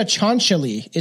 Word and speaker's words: Cianciulli: I'm looking up the pronunciation. C-I-A-N-C-I. Sinciulli. – Leonardo Cianciulli. – Cianciulli? – It sounds like Leonardo Cianciulli. – Leonardo Cianciulli is supposed to be how it's pronounced.